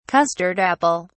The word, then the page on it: custard apple: British English